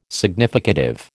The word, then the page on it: significative: American English